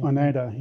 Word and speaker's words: Oneida: She's based out of London right now, and don't want to say this wrong, but she's Oneida. – Oneida, yeah, exactly.